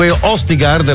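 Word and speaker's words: Østigård: Nico Østigard, la pronuncia è giusta? – Sì.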